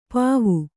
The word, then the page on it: ಪೂವು: pūvu